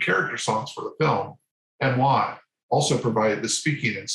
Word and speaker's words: Emoi: Emois. It's spelled E-M-O-I.